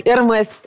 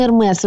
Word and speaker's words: Hermès: Мені заклювали, сказали, та неправильно Ермес вимовляти, треба без С. ні, це все-таки ім'я і поэтому оно никак э, не попадає під правила. – Ну так, тому що насправді в французькій мові багато таких виключень, коли є Ермес, чи є такий насправді актор Ромен Дю Ромен, Ромен Дюріс, тобто вони Дюрія Дюріс.